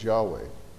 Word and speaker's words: Yahweh: But the special covenant name by which he revealed himself is designated in your translations with all caps for L-O-R-D, and that's translating the word Yahweh. There are four consonants in Hebrew, which are Yod, He, Vav, He, Y-H-W-H. And so that's ordinarily pronounced by scholars as Yahweh.